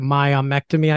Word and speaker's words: myomectomy: or you're gonna have to help me with this word, myomectomy, I believe it's pronounced.